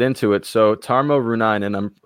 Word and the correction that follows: Reunanen: Pronunciation is tricky with some European names, but his last name is pronounced Reunanen, so...